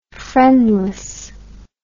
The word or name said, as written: friendlessness